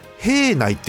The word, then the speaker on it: 平内: あとこれは平内って読むんだね平内と書いてね